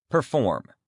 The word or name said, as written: perform